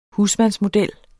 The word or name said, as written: husmandsmodel